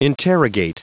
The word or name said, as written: interrogate